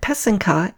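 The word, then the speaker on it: pysanka: I mentioned in episode 1 that pesenka is the singular for Ukrainian eggs using the wax-resist method to write traditional folk designs.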